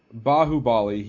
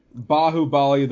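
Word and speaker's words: Baahubali: He came out, I think the name is pronounced Bahubali.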